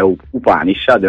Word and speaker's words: Upanishad: Ma, guarda, pensa che dalle 20:30 alle 20:59 ha provato a dire Happanished. – Quindi diccelo te come si pronuncia bene. – Più o meno come si legge, perché è Upanishad.